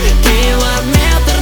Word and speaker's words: километр: Меры длины, корень метр, они километр, например, ударение на Е.